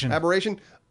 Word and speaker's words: Aberration: You can transfer items from abortion. How do you say it? – Aberration.